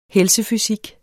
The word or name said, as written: helsefysik